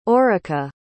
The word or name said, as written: aurica